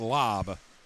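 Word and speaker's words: Lajb: And Lob, by the way, is not spelled like Lob. It's L-A-J-B, but they told me to say it Lob.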